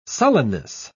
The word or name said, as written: sullenness